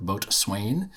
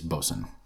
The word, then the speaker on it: Boatswain: But they do all the Boatswain, which I incorrectly called Boatswain, which is how it's written. But if you pronounce it the right way, it's Boatswain. So don't send me any hate mail on that.